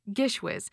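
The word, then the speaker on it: GISHWHES: And I want to check, Gishwiz. I'm saying that correctly, right? – Amazingly, because very few people do.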